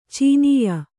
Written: ಚುನಾಯಿ

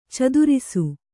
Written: ಚದುರಿಸು

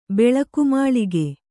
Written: ಬೆಳಕುಮಾಳಿಗೆ

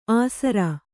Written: ಆಸರ

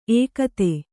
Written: ಏಕತ

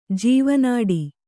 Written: ಜೀವನಾಡಿ

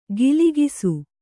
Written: ಗಿಲಿಗಿಸು

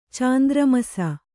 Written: ಚಾಂದ್ರಮಸ

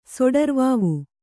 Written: ಸೊಡರ್ವಾವು

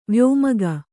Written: ವ್ಯೋಮಗ

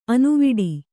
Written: ಅನುವಿಡಿ

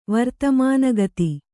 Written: ವರ್ತಮಾನಗತಿ

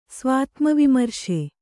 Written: ಸ್ವಾತ್ಮವಿಮರ್ಶೆ

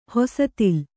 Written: ಹೊಸತಿಲ್

